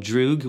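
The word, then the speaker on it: drug (друг): I mean, that's kind of the whole record is sort of like the very first song is called Drug, which it's the Russian word for friend. So you say it like drug would be the more proper pronunciation.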